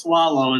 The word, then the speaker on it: Soileau: But just for the listeners, it's pronounced swallow and it's spelled S-O-I-L-E-A-U.